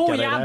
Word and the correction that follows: bouillabaisse: det. – Bouillabaisse kan du då säga.